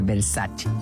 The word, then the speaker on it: Versace: Por ejemplo, Versace, cuando la marca Versace es italiana, entonces tienen que pronunciar Versace.